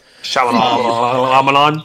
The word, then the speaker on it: Shyamalan: Night Shyamalan, whatever you say his name, movie. – Shyamalan.